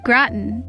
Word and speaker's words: Groton: Groton, not Groton. Groton. Gra, like crawdad.